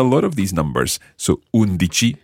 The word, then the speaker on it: undici: Okay, so all of those numbers so far use that dici ending. And the stress goes on whichever syllable comes before the dici ending. Undici, dodici, tredici, quattordici, quindici, sedici.